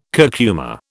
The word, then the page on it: curcuma: British English